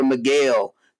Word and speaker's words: Miguel: M stands for Miguel. Kind of rhymes with Gabriel.